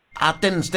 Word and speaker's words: Athens: Athens, Texas.